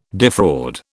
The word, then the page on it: defraud: British English